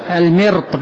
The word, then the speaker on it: المرط: والمرط، الأصل في المرط هو المرط وليس المرط، المرط بكسر الميم، بكسر الميم، المرط، المرط بكسر الميم، أنا نطقتها خطأ.